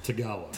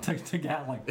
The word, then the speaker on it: Tagalog: Tagalog. – Tagalog.